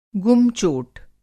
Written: गुम चोट

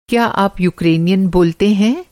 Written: यूकरेनियन

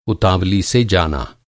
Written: उतावली

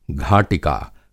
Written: घाटिका

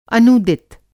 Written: अनूदित

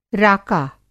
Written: राका

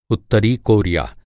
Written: कोरिया

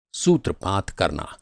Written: सूत्रपात

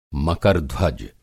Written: मकर-ध्वज

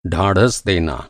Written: ढाढ़स

